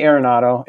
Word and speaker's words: Arenado: So if you have someone like Nolan Arenado who can... Nolan, did I say it right? Did I say Arenado? It's, yes, Arenado.